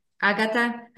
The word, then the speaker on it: Agata: The first name is very simple, as you hear. Agata. I know that in Portuguese we add a little accent and it's Agata, it's more singing, but in Polish it's just Agata.